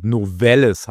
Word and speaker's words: Novelis: 40% des Aluminiumblechs in den Vereinigten Staaten wird von der Firma Nöllis erfüllt für die US-Autoindustrie. Ford ist einer der größten Abnehmer, und hier aufgrund eines Brands also wird es zu Ausfällen kommen. Novelis heißt das Unternehmen, hier nochmal korrekt ausgesprochen.